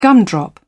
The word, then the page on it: gumdrop: British English